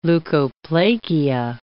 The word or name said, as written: leukoplakia